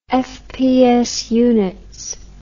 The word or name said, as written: fps units